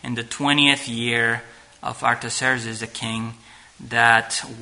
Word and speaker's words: Artaxerxes: This is the decree by King Artaxerxes, I believe that's how you say it. Artaxerxes. He was a king of the Medo-Persian Empire.